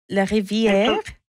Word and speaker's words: Larivière: Is it Larivière? – Well, in French, it's Larivière. Yes.